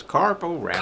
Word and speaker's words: Carp-O-Rama: But, yeah, I just like to say it like that. – It is Carp-O-Rama. – Carp-O-Rama. It's the annual Carp-O-Rama at Lake Maloney.